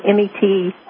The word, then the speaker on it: metoprolol: One is clonidine, one is hydrochlorot, one is metrolol. I can't pronounce it. Metoprolol. – It's called metoprolol.